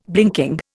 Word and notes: blinking: Indian

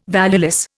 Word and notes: valueless: Indian

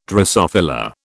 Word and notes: drosophila: British English

